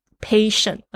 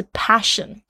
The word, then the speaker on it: passion: 哦，就因为我一个字的那个 A 的发音错了，就不是热情，他以为我在讲说我的耐心或者是我的病人，他听不懂，他说不是 patient，而是 passion，就那个 A 的发音。